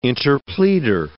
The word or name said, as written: interpleader